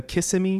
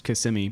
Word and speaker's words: Kissimmee: I uh, you know, growing up in Florida, a lot of people say, uh, "Yeah, it's really close to Kissimmee." It's like it's actually pronounced Kissimmee, but you're an outsider and no one likes you, so it's hard.